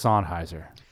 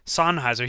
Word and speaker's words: Sennheiser: I think it's Sennheiser. – Sennheiser.